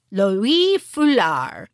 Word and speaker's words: Loïe Fuller: Also in it, the person who's reading it must speak French because she pronounces her name Loïe Fuller, which I couldn't do.